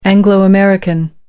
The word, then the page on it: anglo american: American English